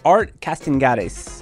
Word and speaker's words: Art Castañares: And today I'm joined by Art Castañares.